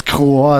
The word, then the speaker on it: Kroer: Und von dieser besagten Demo habe ich mal den Song Gefreiter Kroer mitgebracht. Oder wie spricht man das aus? Du bist der. – Also musst du mal fragen. – Du musst das wissen. – Kroer ist richtig.